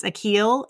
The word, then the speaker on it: Achill: But when I was reading about it later, actually, they filmed it on, I'm not sure I'm saying it right. I think it's Achill. It's A C H I L L mm-hmm.